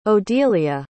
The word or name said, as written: odilia